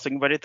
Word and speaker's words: Ogunbode: My name is Charles Ogunbode.